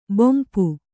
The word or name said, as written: ಬಂಪು